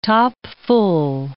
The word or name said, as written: topful